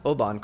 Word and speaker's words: Oban: Hey, well, you pronounced Oban correctly. I believe that was the right way to say it.